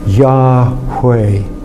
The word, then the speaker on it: Yahweh: So, for example, when you are doing the breathing of mindfulness, the inspiration would be on the first syllable, ya, and you would do the expiration on hui. Yahweh, Yahweh.